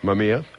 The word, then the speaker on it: Mamiya: I have this tremendous urge to say Mama, but okay. Mamiya. – Yeah. – How do you spell it? I've never heard of it. – Mamiya. – Okay, I've seen it. I just didn't pronounce it right. Okay, Mamiya.